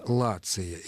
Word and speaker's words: Лация: Но дело в том, что следует спросить, откуда берется название «Лация». Если мы этим вопросом зададимся, то окажется, что произносится «ц», а пишется то же самое «т».